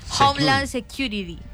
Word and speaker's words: Homeland Security: Fue firmada una alianza con el Homeland Security. ¿Cómo se dice? – Homeland Security. – Security. – Sí, señor.